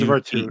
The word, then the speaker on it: Virtute: The plea from a cat named Virtute or Virtu. It's spelled V-I-R-T-U-T-E. Oh, it's Virtue. I looked. There's a hidden T that I didn't see.